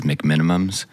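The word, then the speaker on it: McMenamins: Everyone that I know says McMinimums. – Oh, yeah, that's true. – But it's spelled McMinimins. – That's true, like... – I think I say McMinimins.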